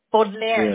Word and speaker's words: Baudelaire: Baudelaire.